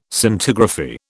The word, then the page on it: Scintigraphy: British English